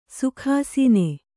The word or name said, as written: ಸುಖಾಸನ